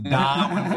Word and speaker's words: Darwin: Hey, yeah, it's pronounced Darwin with like three A's.